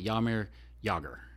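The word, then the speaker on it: Jaromír Jágr: And so the J is like a almost like a Y. Jaromir Jagr.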